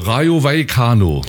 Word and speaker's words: Rayo Vallecano: Rayo Vallecano. Rayo, nicht Radio Vaticano, sondern Rayo Vallecano. So ungefähr.